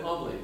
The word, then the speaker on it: oblate: Oh, an oblate. Thank you for asking. – How do you pronounce it? – It's O-B-L-A-T-E. Oblate. O-B-L-A-T-E. – Like an oblation. – Yeah, like oblation.